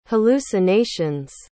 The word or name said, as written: hallucinations